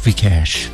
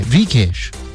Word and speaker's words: Vikash: They make it sound so cool. Vikash. Yeah, Vikash. UK, Vikash. Then the Indians. It's an Indian name, but they won't know how to pronounce it. They always eat the H. Ramesh Mukesh Suresh thing you do. Don't do that with my name.